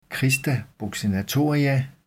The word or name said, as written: crista buccinatoria